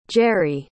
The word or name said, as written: gery